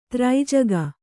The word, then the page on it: ತ್ರೈಜಗ: kannada